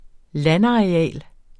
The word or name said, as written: landareal